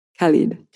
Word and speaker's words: Khalid: Khalid.